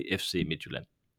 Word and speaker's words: Midtjylland: You you can you can you can pronounce it uh, in different ways, but if you want to to do it in the local dialect of FC Midtjylland, you say FC Midtjylland.